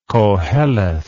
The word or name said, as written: Koheleth